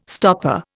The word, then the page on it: stopper: Indian